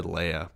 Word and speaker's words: Leia: Are really you're gonna even say Leia different than I do? – How would you pronounce it? – I guess it is. I said Leia, but you're right. It's definitely Leia.